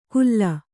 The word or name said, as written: ಖುಲ್ಲ